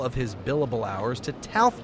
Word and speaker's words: TALF: Enter the federal government last November with a program called TALF. It rhymes with ALF, I know, but the only alien life forms here are the asset-backed securities.